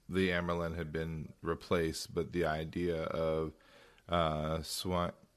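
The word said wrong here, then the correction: Siuan: oh, can I say her name? – Swan. – Swan, thank you, being stilled and killed, like that is something that's been going around, but they hadn't heard that part of things.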